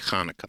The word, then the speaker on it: Hanukkah: And a lot of Gentiles have difficulties with ch. So even though it would be it could be spelled with a ch, you'll have people say Hanukkah all the time. I say Hanukkah. – Okay, so there's a little guttural there.